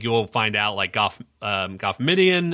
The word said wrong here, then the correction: Moff Gideon: um, am I saying that right? Moff Gideon. Moff sorry, Gideon.